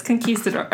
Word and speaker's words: conquistador: That's how they pronounce it. It's conquistador. Conquistador. Yeah, conquistador.